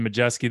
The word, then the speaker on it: Majewski: We now welcome in Jordan Majewski. This is like kind of a first one. I actually pronounced his name correctly, 'cause now I know how to do it.